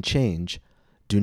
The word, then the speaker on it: changed: The E is silent. Students often comment that a word like changed sounds like it has two syllables, but it does not.